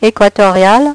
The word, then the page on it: equatorial: French